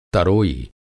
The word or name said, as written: तरोई